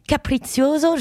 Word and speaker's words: Capriccioso: il existe une école de musique qui s'appelle Capriccioso. Je le dis bien parce que mon accent, c'est italien, c'est ça? – C'est italien, mais absolument, tu l'as bien dit.